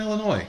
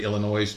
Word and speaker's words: Illinois: Because I know how they hate the S being pronounced in Illinois, so I'm going to say Illinois because I don't fucking care.